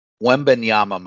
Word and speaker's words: Wembanyama: How do you say his last name? Wembaya? Wembaya is my understanding, yeah. Why did I think it was Wembanyama? Why did you let me pronounce it like that earlier? – Let me look up how it's spelled because now you got me. – Oh, this is this is great podcasting. – Wembanyama might be right actually. – Wembanyama. Okay, okay.